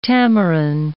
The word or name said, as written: tamarin